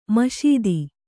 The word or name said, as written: ಮಸೆತ